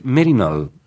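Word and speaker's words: Maryknoll: Se dice Merinol. Uh-huh. Siempre me cuesta pronunciarlo.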